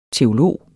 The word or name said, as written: teolog